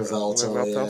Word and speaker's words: Revuelto: Revuelto.